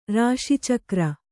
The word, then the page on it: ರಾಶಿಚಕ್ರ: Kannada